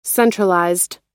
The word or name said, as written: centralized